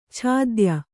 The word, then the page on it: ಛಾದ್ಯ: chādya; Kannada